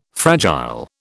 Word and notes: fragile: British English

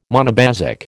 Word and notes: monobasic: American English

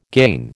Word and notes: gain: American English

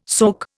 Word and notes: soke: Indian

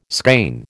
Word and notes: skein: American English

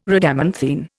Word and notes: Rhadamanthine: Indian

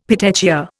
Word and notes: petechia: Indian

